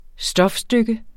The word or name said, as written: stofstykke